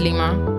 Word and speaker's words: lima: It is Lima. – I knew it. Lima is the correct way to say lime.